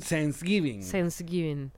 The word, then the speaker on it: Thanksgiving: Thanksgiving. Que eso no era una serie, Sense8. – Es que tratas como de pronunciar la TH con Thanksgiving.